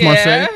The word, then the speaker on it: Marseille: Is that Marseille? Marseille. That's Marseille.